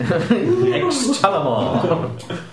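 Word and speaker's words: Xtalamar: It actually starts with a silent X. – X Talamar.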